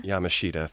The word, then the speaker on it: Yamashita: Yamashita, thank you.